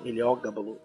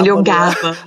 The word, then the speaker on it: Heliogábalo: E já que a gente está tocando o nome desse imperador, Eliogábalo, Eliogábalo, Heliogábalo.